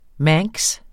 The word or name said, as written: manx